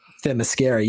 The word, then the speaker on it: Themyscira: Themyscira. – Themyscira.